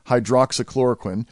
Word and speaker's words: hydroxychloroquine: And my sincere apologies to all you doctors out there who know I've been mispronouncing that. It's hydroxychloroquine. sulfate, which President Trump has cited as a potential game changer in treating coronavirus.